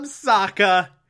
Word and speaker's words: Sokka: That's right, I'm Sokka. It's pronounced with an akka.